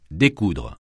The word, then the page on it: découdre: French